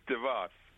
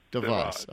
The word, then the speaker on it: DeVos: Is it DeVos, DeVos, DeVos, DeVos? – DeVos. – DeVos. Okay, so I got it right the first time.